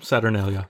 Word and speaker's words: Saturnalia: Saturnalia? – Mm-hmm, Saturnalia.